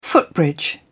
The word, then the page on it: footbridge: British English